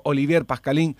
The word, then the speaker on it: Olivier Pascalín: Está en contacto con nosotros el amigo Olivier Pascalín. Olivier, ¿cómo andás? Buen día, acá en la radio te saludamos, ¿todo bien? – Igual les saludo con todo el corazón. Muy bien la pronunciación en francés.